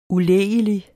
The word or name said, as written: ulægelig